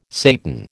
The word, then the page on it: Satan: American English